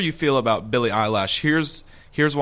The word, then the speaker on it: Eilish: Eilish, pronounced Eilish.